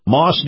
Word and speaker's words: Maus: Anyway, this portion of the program, my conversation with Lieutenant Colonel Kevin Wright, brought to you by our friends at Moss Nissan. Now, Moss is spelled M-A-U-S,